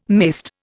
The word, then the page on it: mayst: Indian